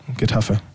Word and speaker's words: Getafe: Spricht man das echt so? – Besser, ja. Getafe.